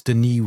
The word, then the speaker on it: Denis: By the way, it's Denis with a silent S because he's Canadian and French.